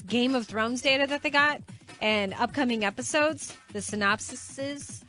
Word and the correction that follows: synopsis: The synopsis. – Synopsis. – Synopsis is the correct pronunciation.